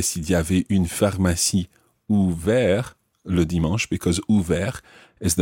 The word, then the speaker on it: ouvert: So, ouvert ends in a T, which is silent. Ouverte ends in the TE, making the T pronounced.